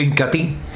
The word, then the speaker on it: Incatea: Se escribe Incatea, pero se pronuncia Incati.